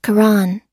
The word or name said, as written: koran